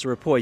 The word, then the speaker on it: Ripoll: Ripoll, is that how we pronounce the name, Frank? – Yes, it's Ripoll. You got it just right.